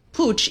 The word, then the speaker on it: Puig: And by the way, do pronounce it Puig, even though it's spelled P-U-I-G.